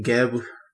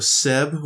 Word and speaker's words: Geb: His name is pronounced a few ways, depending on what period. So not only is it Geb, it's also Seb with an S and Keb with a K.